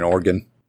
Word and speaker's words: Oregon: That's what they think they are in Oregon. – You pronounced it correctly.